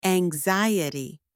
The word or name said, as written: anxiety